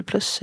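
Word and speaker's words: Pluess: Eller Plus är det uttalas och en annan kvinna som heter Bianca Acevedo.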